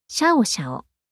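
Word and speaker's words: 暁暁: 決定した名前、オス、カタカナ名でシャオシャオ。漢字は、暁という漢字、二つを並べてシャオシャオと読みます。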